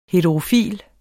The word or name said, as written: heterofil